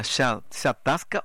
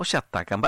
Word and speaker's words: chataca: ¿Cómo se dice, directora? ¿Chatasca o chataca? Esa se pronuncia. – Se hay cual chataca con reviro.